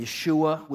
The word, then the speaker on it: Yeshua: Yeshua would be the way it would be pronounced to pronounce that properly in Hebrew.